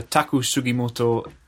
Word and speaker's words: Taku Sugimoto: I'd like to start with something a little bit different, which is a David Toop review of a Taku Sugimoto album, live album called Italia.